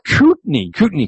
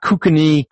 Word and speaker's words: Kootenai: The infamous pastor Jim Osmond from Kootenai Community Church. – Yeah. Yes. – I got to make sure I pronounce it right, because it's not Kukani, Kukami, Kukarakami, Kuka something, Kikami in the Shin.